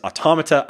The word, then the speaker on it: Automata: And yes, it is Automata. I'm not bending on the pronunciation. I know the developer pronounces it differently. Automata is... – How do they say it? – I think he says automata, but automata is the plural of automaton. It is the correct pronunciation.